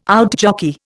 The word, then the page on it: outjockey: Indian